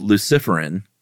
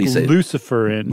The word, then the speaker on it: luciferin: And it contains, like you said, that chemical called luciferin. – Sure. – And it makes an enzyme called luciferase. – If you don't want to sound devilish about it, that would be a fine pronunciation. I like luciferin.